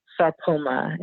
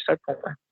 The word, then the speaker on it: Sarpoma: You go to my Instagram or even my Facebook or YouTube at Dr. Sarpoma, and it's just the way you spell, you say my name, it's just Dr. Sarpoma.